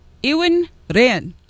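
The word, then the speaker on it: Iwan Rheon: It's Ewan Ryan. – Whatever you say. – Yes. – Okay. – That's how he pronounces it. He has a little roll of the tongue there.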